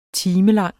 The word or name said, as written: timelang